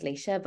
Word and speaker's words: Leisha: But actually, somebody recently said to me, I don't know what you'll think about this, because my name um is kind of like it's an Irish name, but someone um obviously it's pronounced Leisha, but someone actually said to me, Why don't you just write it in phonetics on your CV?